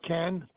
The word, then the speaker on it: Ken: Yeah, my first name's Ken, K-E-N. Last name is Heavener, H-E-A-V-E-N-E-R. – Hold on, spell that again, H-E-N. – H-E-A, V as in Victor, E-N-E-R.